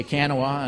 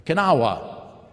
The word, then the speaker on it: Kanawha: Because you say Kanawha, and it say, no, they say it, it's like two syllables.